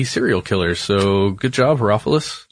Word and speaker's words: Herophilus: Herophilus.